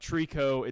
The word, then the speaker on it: Tricot: Trico. It's spelled Tricot.